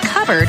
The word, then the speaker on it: cupboard: When we come back, why it's pronounced covered when it's spelled cupboard.